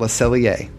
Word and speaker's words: Le Cellier: And that tradition is at Le Cellier. Said it right? – Yeah, finally.